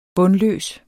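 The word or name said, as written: BUNDLØS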